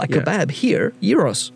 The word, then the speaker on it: gyros: No, they're called... it's spelled G-Y-R-O-S. Some of them write it with a G. Some of them write it with a Y. It's Gyros, it's Euros.